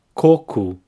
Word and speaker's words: coco: This word is quite similar to the Portuguese word for coconut, coco. Now, here the emphasis is in the first syllable, coco, and the O at the end has an U sound, ku.